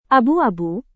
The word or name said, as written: abu-abu